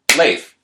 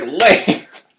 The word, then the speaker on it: Leif: It's like the clap, Leif, like one syllable, people.